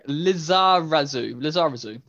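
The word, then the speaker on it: Lizarazu: Lazarazu, there we go.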